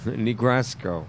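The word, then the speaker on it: Negro Scoe: That's right, but we're talking about Kansas City because I'm featuring my buddy Negro Sco.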